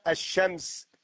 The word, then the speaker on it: الشمس: لو أدخلنا عليها الألف واللام وقلنا الشمس الشمس ونقف هكذا، لو كتبنا الشمس بالرسم الإملائي ألف لام شين ميم سين، ألف لام الشمس، إذن نحن ننطق ألفا بعدها شين مشددة، مع أننا كتبنا في الرسم الإملائي ألف لام، ولذا لو كتبنا بالرسم العروضي لا نكتب لاما، الشمس ألف مفتوحة شين ساكنة شين مفتوحة ميم سين.